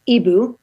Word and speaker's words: hibou: i like the word for owl in french which is ibu but they spell it h-i-b-o-u but we didn't want people to say Hi boo i actually like the sound ibu